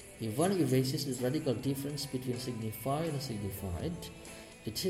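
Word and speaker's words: différance: gitu yang kemudian juga nyambung dengan difference uh, eh, di bahasa Perancisnya dibacanya pengucapannya difference uh, eh, nulisnya mirip di dalam bahasa Inggris difference uh, tapi e yang pertama itu ada curapnya difference jadi bacanya e difference nah uh,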